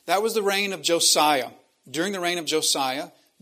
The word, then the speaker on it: Josiah: So Daniel would have been born under the kingship of the last good king of Judah, known as King Josiah, or more properly pronounced King Yosiah.